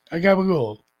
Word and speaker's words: gabagool: Tony Yabagool. – Tony, you didn't say it right. – Gabagool. – Tony Gabagool.